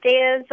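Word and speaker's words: Deya: Hi, my name is Daya Zavalas.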